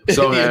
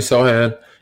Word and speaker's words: Sochan: Jeremy Sochan. I don't know how to pronounce his last name. Messing it up. – Sochan. You're good. – Jeremy Sochan.